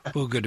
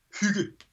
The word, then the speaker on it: hygge: What are the origins of this term, hygge, which, of course, is not spelt like anybody who speaks English would expect hygge to be spelled? H-Y-G-G-E? – It's H-Y-G-G-E, yeah. We pronounce it hygge. So I think your Danish pronunciation is quite good, actually.